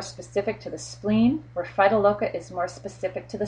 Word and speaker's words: Phytolacca: If you can look back and see that the tonsils are red or inflamed, then, you know, I like to give Phytoloca. Phytoloca, or some people pronounce it Phytolacca,